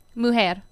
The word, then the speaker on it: Mujer: Mujer. – Mujer.